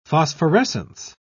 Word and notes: phosphorescence: British English

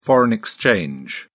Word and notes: foreign exchange: British English

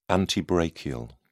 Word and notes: antebrachial: British English

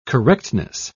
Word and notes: correctness: British English

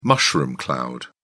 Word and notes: mushroom cloud: British English

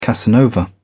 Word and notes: casanova: British English

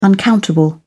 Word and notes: uncountable: British English

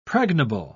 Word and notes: pregnable: British English

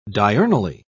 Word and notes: diurnally: British English

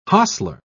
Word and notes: hostler: British English

